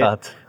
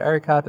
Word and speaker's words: Ereiqat: I don't want to butcher your surname, if you could say Erekat. – Erekat, thank you for that. Say hello, how are you doing? – Hey, my name is Saqar Erekat.